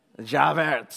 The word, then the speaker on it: Javert: But Inspector Javert, if I said his name right, help me, literature majors. – Javert, there we go.